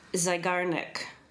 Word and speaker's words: Zeigarnik: They think that the bullet journal is helping to shut down a psychological phenomenon known as the Zeigarnik effect. – Yeah. – How would you say that? – Let's have a look. – Zeigarnik. – Yeah, Zeigarnik. – Zeigarnik effect.